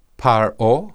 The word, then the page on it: pharoh: Hebrew